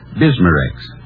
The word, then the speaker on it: Bismarex: Bismarex. Is that how you say it? – That's right, ma'am. D-I-S-M-A hyphen R-E-X. Bismarex.